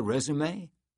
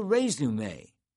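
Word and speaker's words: résumé: But Eunice immediately brightened and said, the resume. – She even pronounced it foreignly, with a long A in the first syllable.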